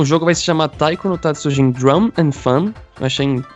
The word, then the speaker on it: Taiko no Tatsujin: Taiko no Tatsujin, eu falei certo, João? – Falou certo, tá de parabéns.